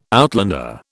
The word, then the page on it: outlander: British English